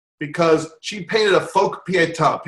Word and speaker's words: Pietà: Pietà, it's spelled P-I-E-T-A.